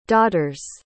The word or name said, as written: daughters